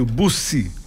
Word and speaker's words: Buzzi: lá a gente chama de Marco Aurélio Buzzi, o 2E com a pronúncia de S.